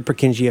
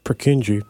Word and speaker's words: Purkinje: I heard it called the Purkinje effect. It's from Czech. It's a Czech word. Um, okay, so setting aside that I don't know how to pronounce this, the Purkinje effect. I learned about this in intro psych. The Purkinje effect, sometimes called the Purkinje shift, so often incorrectly pronounced Purkinje, is the tendency.